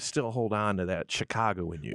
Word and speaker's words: Chicago: And I want you to pronounce Chicago the right way, It's Chicago. – Yeah. – Chicago. – Not the way I say it.